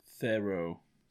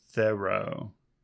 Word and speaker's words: Thoreau: Thoreau. – Thoreau. – With the stress on the second thoreau. – Syllable.